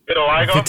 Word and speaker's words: vitiligo: Vitiligo.